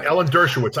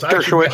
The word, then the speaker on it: Dershowitz: You know, I heard Alan Dershowitz. I don't even know if I'm pronouncing his name. – Yeah, Alan Dershowitz. Dershowitz.